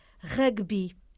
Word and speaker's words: râguebi: R-A, acento circunflexo, G-U-E-B-I. A escrita. Pronúncia, rugby. – É pronto, é palavra esdrúxula.